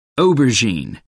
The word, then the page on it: aubergine: American English